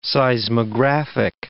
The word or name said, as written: seismographic